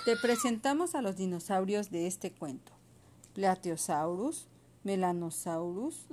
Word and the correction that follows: Melanorosaurus: Melanorosaurus, Melanorosaurus, gracias, Eoraptor.